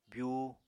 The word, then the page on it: byuu: apatani